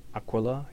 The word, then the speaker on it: Aquila: One that has the Aquila. Did I say that right? Aquila? Yeah.